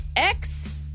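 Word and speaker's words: X Æ A-12: But this one is X-I-A-12. – Yep. I thought you said you wouldn't pronounce it. You nailed it.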